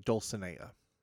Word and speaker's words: Dulcinea: The correct pronunciation is actually Dulcinea.